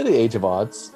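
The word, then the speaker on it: The Age of Adz: And then 2010, about a month later, he has his official release, The Age of Odds, which is, Canadians call it The Age of Ads. Right? I heard... – until I heard someone else say it right, and I was like, oh. – How do you pronounce it? – I say The Age of Odds. – Okay? – I would say Age of Odds as well.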